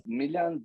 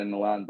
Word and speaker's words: Miljan: Miljan Bajic, but I go by Milan Bajic because in Serbo-Croatian, most people can't pronounce it. Yeah, so for the last 27 years, people know me as Milan, except if you're from the Balkans, or you can pronounce that L and J as yeah.